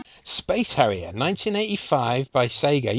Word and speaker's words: Sega: and she said Sega. – Oh. – So that's it. That's how you say it.